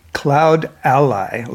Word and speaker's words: CloudAlly: CloudAlly, like the allies. – Damn. – Exactly. See, I knew that was the thing. – You were right. – Your ally in the cloud. – Ally in the cloud.